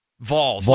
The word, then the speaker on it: Vols: Vols. Like pretend that you're pronouncing V-A-W-L-S in your accent.